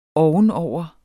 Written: ovenover

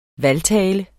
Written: valgtale

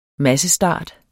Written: massestart

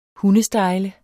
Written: hundestejle

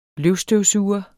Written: løvstøvsuger

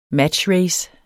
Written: matchrace

